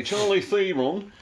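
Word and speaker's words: Charlize Theron: Charlie Theron.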